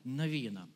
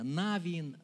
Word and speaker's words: Навин: Откройте книгу Иисуса Навина. В разных церквях по-разному делают ударение. Навин, Навин, не судите строго, это не очень важно в данной теме.